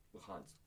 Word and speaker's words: Luhansk: Wait, how do you say these places? – Donetsk and fucking Luhansk.